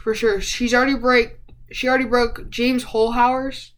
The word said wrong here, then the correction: Holzhauer: James Hole. I don't really know how to pronounce that. Holzhauer. – Yes, thank you.